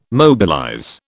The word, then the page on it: mobilize: British English